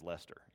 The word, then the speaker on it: Leicester: Now, I looked this up because it doesn't look like Lester, but the pronunciation I could find says Lester, even though it looks like something else.